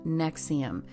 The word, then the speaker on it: NXIVM: Just a few years later, it was rebranded under the name Nexium, but it's spelled NXIVM like like Roman numerals.